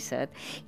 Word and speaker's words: set: La majoria pronuncia amb la E neutra, o sigui E, per referir-nos a la necessitat de beure, tenir set.